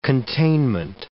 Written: containment

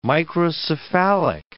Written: microcephalic

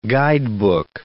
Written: guidebook

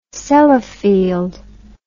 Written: Sellafield

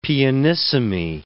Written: pianissimi

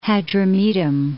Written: Hadrumetum

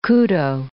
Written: kudo

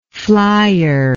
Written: flyer

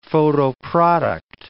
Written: photoproduct

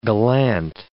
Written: gallant

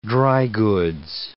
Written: dry goods

